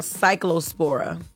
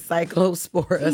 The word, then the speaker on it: Cyclospora: Got to correct you about pronunciation. – No, yo, chill, yo, please, just relax. This is Cyclospora.